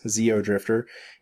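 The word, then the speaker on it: Xeodrifter: Well, speaking of games that are actually out, I have today played maybe an hour of Zeo Drifter. That's spelled with an X, Zeo Drifter, and it is the new 3DS eShop game from Renegade Kid.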